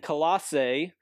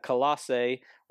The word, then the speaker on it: Colossae: You see Ephesians right along the coast, and then Colossae over here is the way you would pronounce that, Colossae, right along the Lycus River, about 100 miles to the east of Ephesus.